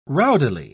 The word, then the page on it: rowdily: British English